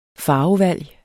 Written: farvevalg

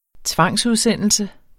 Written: tvangsudsendelse

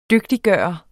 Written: DYGTIGGJORT

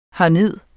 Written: herned

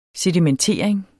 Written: sedimentering

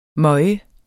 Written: møge

